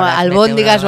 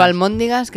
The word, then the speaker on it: albóndigas: Esto es como albóndigas o almóndigas, que nunca se sabe cómo se dice.